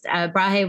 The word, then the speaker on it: Brahe: Just a side note, he was actually just, Brahe was I didn't know that's how you pronounced it.